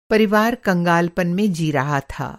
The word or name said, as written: कंगालपन